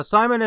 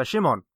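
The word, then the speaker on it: Szymon: Yeah, like Simon is how you would, I guess, you know, say it just in English, but with all the Polish pronunciation, it is Shimon.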